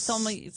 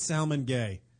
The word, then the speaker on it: sommelier: So isn't it sommelier? Sommelier. I said it right. – No, you said salmon gay.